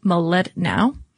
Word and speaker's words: Mlodinow: And there is a really interesting book, Leonard Medlanov. Excuse me, let me try that again, because I'm sure that at least his family would appreciate that I pronounced it correctly. But Leonard Mlodinow.